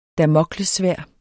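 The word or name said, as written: DAMOKLESSVÆRD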